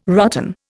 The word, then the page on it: rotten: Indian